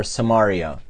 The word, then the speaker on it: Samaria: And they probably did everything they could to avoid going deep into the heart of Samaria, or Samaria, as more properly pronounced.